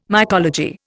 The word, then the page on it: mycology: Indian